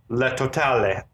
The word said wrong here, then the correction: La Totale: La Totale.